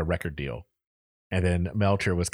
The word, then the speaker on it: Melcher: So Wilson, the Beach Boy, before then had introduced Manson to a man named Terry Melcher, M-E-L-C-H-E-R, Melcher. – Okay. – I think that's how you say it.